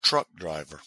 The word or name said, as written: truck driver